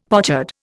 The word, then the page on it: pochard: Indian